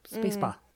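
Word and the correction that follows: spasiba (спасибо): Isn't it spasiba? – Spasiba. – Spasiba, excuse me. – Spasiba. – I had the word right, but the pronunciation wrong.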